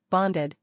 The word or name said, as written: Bonded